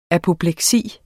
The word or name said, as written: apopleksi